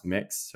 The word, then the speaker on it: Mx: the gender neutral one is MX." which is pronounced Mix, so it'd be like Mix Davies.